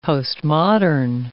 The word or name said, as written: postmodern